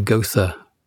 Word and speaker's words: Goethe: And I think you pronounce his name Goethe.